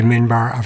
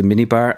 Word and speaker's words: minbar: Because I sometimes mispronounce it as the minibar, and it's not the minibar.